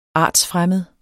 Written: artsfremmed

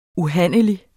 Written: UHANDLELIGT

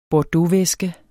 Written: bordeauxvæske

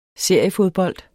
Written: seriefodbold

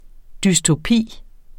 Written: dystopi

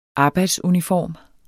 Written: arbejdsuniform

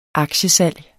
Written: aktiesalg

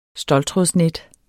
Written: ståltrådsnet

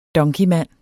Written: donkeymand

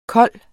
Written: kold

